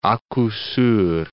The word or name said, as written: a coup sur